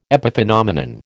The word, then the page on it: epiphenomenon: American English